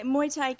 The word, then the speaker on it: Muay Thai: But then you discovered, am I saying it right, Muay Thai? – Yeah, so that was later. So I became vegan. Yeah, Muay Thai kickboxing.